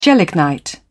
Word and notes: gelignite: British English

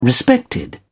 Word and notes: respected: British English

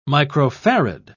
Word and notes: microfarad: British English

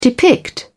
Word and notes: depict: British English

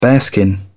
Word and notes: bearskin: British English

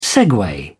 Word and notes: segue: British English

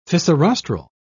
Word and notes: fissirostral: British English

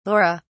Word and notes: laura: British English

